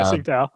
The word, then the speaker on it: Tsingtao: We're gonna drink Tsingtao instead. I don't know if I'm pronouncing that correctly. – No, you got it. Yeah, Tsingtao.